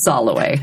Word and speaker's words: Soloway: So for example, my last name is Soloway. – Mm-hmm. – It's spelled like it's solo way.